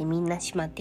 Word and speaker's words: 水納島: あのガラガラですえっ、ー、と昨日に引き続き今日は沖縄の離島編ということでえっ、ー、とみんな島というところのご紹介をしたいと思いますあの水に収めるに島って書いてみんな島って読むんですけれども